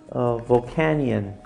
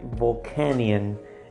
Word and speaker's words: Volcanion: So anyways, so that was the Shining, and I just now understood how to pronounce it is Shining Volcanion, which makes sense, right? It's like a cannon, right? But Volcanion is the way that they want to pronounce it.